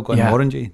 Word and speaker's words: Glenmorangie: Yeah, and you got it as well, Glenmorangie. Yeah. Nailed the pronunciation. – Finally. So it took like four times. But then I was like, I'll start rolling the R's and stuff. – Just that's what you want to do. You want to speak a Scottish accent, roll your R's.